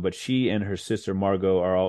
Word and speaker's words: Margot: Is, you know, so how do you pronounce it? Margaret? Margaret? – Margot. – Oh, wow. It's French.